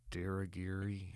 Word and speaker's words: Dirigiri: Diragiri.